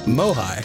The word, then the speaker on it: MOHAI: They're Mohai. Having this, yeah, Mohai. That's Mohai. What we all call it, Mohai. Yeah. – It's because it's spelled M-O-H-A-I. Mohai.